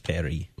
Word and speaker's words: Paris: You don't pronounce the S if you're going for the French version. It's Paris.